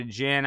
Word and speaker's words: djinn: So, but, and they say he conjured a djinn.